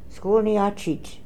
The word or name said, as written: Skoolniatchit